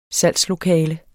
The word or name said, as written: SALGSLOKALETS